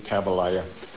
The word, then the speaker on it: Tabalaya: so I changed it to Tabalaya. Rhymes with Jeremiah, comes from the Old Testament, VE6UX.